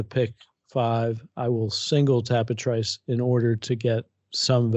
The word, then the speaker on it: Tapit Trice: And his dam, Dan Satrice, I'm assuming it's Dan Satrice because his name is pronounced Tapit Trice. At least according to John Imbriel.